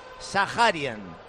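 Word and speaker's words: Zakharyan: Por cierto, nos ha dicho que se pronuncia, ya lo dejamos bautizado de aquí para siempre, Sajarian. Así que a partir de ahora, Arsène Sajarian.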